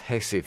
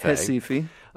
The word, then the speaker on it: Recife: So you went to what looks like Recife, but is pronounced Recife. – Recife.